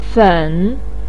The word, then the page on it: fen3: pinyin